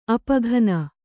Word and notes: ಅಪಘನ: apaghana

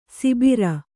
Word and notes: ಸಿಬಿರ: sibira

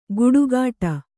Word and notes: ಗುಡುಗಾಟ: guḍugāṭa

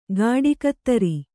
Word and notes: ಗಾಡಿಕತ್ತರಿ: gāḍi kattari

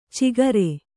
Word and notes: ಚಿಗರೆ: cigare

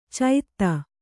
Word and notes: ಚೈತ: caita

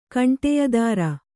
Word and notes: ಕಂಟಿಯದಾರ: Kannada

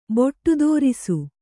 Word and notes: ಬೊಟ್ಟುದೋರಿಸು: boṭṭudōrisu

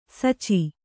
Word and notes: ಸಾಚ: Kannada

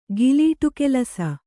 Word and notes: ಗಿಲೀಟುಕೆಲಸ: gilītu kelasa

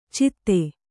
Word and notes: ಚಿತ್ತೆ: citte

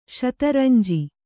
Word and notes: ಶತರಂಜಿ: śata ranji

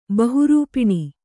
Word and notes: ಬಹುರೂಪಿಣಿ: Kannada

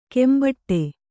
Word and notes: ಕೆಂಬಟ್ಟೆ: kannada

